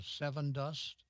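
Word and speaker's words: Sevin dust: I think what you might try is maybe a little bit of seven dust, S-E-V-I-N, that's how you spell it, seven dust, which is an insecticide that is labeled for vegetables, if you will.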